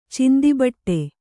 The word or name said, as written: ಚಿಂದಿಬಟ್ಟೆ